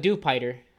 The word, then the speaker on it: Dewpider: Dewpider.